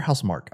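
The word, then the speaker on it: Housemarque: Is it House Marquee or House Mark? – I would say House Mark, but honestly, it's not. – Okay, House Mark. I think it's House Mark, and I've heard that, and I said it wrong the first time for some reason.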